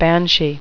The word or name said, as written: banshee